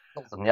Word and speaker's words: Pilsen: Pilsen. – Pilsen, yeah.